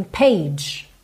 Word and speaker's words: page: Porque esse G, ele tem esse som J, J. Como se fosse um DJ, J. O E é mudo. Então, a gente tem page, page.